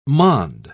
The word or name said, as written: monde